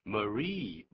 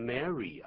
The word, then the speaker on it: Marie: Marie, 不念成 Mary 啊 ,Mary 是另外一个拼法哦。 – Capital M-A-R-Y, 是大写的意思，第一个字母，因为是专有名词，是人名，要大写。